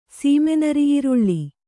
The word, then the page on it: ಸೀಮೆನರಿಈರುಳ್ಳಿ: Kannada